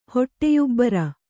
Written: ಹೊಟ್ಟೆಯುಬ್ಬರ

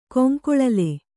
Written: ಕೊಂಕೊಳಲೆ

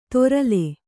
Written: ತೊರಲೆ

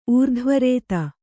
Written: ಊರ್ಧ್ವರೇತ

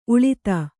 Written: ಉಳಿತ

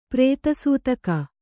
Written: ಪ್ರೇತಸೂತಕ

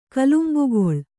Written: ಕಿಲುಂಬುಗೊಳ್

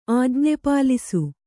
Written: ಆಜ್ಞೆಪಾಲಿಸು

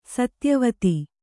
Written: ಸತ್ಯವತಿ

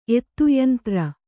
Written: ಎತ್ತುಯಂತ್ರ

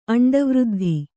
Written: ಅಂಡವೃದ್ಧಿ

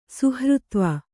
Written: ಸುಹೃತ್ವ

